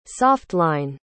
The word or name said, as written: soft-line